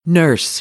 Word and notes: nurse: American English

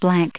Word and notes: blank: adjective

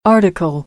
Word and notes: article: American English; ɾ

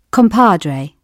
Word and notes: compadre: British English; ɒ

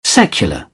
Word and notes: secular: British English

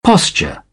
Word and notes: posture: British English; əɹ

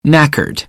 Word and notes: knackered: American English; ɚ

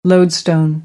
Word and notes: lodestone: American English; oʊ

